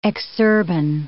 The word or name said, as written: exurban